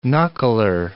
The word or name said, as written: knuckler